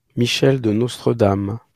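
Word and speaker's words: Michel de Nostredame: So Nostradamus, as we know him, he was born in 1503 in France as, I'm going to try to pronounce this, Michel de Nostradam.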